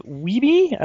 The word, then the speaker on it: Wiebe: and it follows the competitive video game champions Steve Wiebe, I don't know if I'm saying that right, and Billy... – Nope, you're saying it right.